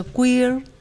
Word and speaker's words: queer: Bueno, esa es una palabra anglosajona, es una expresión que viene del inglés, que se pronuncia queer, y la gente latina, y se escribe Q-U-E-E-R, ¿no?